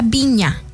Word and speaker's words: Vigna: No sé, dime tú, se pronuncia Viña. Flor Viña, porque se escribe Vigna, pero es Viña, ¿verdad?